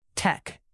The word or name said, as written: tech